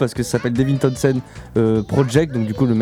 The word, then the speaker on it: Townsend: Alors j'ai très mal prononcé au début, donc c'est Townsend. – Townsend. – Ouais, t'as mal prononcé.